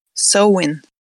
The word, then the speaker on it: Samhain: How else do you pronounce Samhain? – Sowin. – Yeah, it is so instead of sow, in the way that it's being pronounced here.